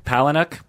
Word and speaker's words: Palahniuk: The novel is by Chuck Palahniuk. – Yeah. – How do you pronounce it? – Palahniuk. – Palahniuk? – I believe it's Palahniuk. – Palahniuk? – Palahniuk.